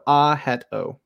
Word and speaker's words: Ajito: So the A sound in Tagalog is ah. So it's ah. There's no J sound. It's H. So Ajito.